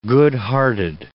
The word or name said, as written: good-hearted